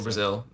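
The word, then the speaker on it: Brasil: Yeah, no, fun fact, when in Brazilian words, if you have a vowel, the letter S and another vowel, it sounds like a Z. So Brazil, Luisa, that's kind of how it goes. So it's like if I were a girl and Luis, it was a Luisa, it would be Luisa.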